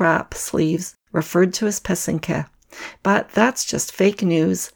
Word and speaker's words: pysanky: So it's spelled P-Y-S-A-N-K-Y. The accent is on the second syllable. An easy way to remember how to say it is that the first and third syllable rhyme. So that's the pe and the ke. Put it all together and you get pesenke.